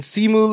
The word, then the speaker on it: Simu: Shang-Chi and Simu Liu.